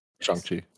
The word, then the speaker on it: Shang-Chi: Maybe we'll see him again in Shang-Chi. Did I say it right? – Yeah. Mm-hmm. Shang-Chi.